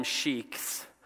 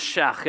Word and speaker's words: sheikh: Here we call them sheikhs, but it's sheikh in Arabic. So you want to do it pronounced, that's free, it's a free lesson here, sheikh, and you kind of, okay, so just cough at the end of it.